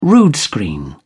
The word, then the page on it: rood screen: British English